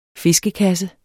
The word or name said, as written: fiskekasse